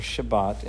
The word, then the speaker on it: Shabbat: So Sabbath, or Shabbat in Hebrew, that's how it's pronounced, is in verse 2, verse 4, and verse 6.